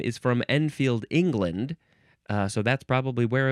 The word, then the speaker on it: Enfield: No, that's not how you say it. It's enfield rifle. Enfield has a capital E, E-N-F-I-E-L-D.